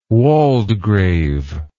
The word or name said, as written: waldgrave